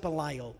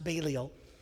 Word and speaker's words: Balliol: Looks like Belial, but it's pronounced Balliol. Belial would be a bad guy in the scripture.